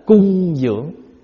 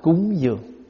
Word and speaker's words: cúng dường: Chữ cúng dường nếu chúng ta đọc đúng chữ là cung dưỡng. Cung dưỡng là gì? Là cung cấp để dưỡng nuôi. Và bây giờ chúng ta đọc là cúng dường.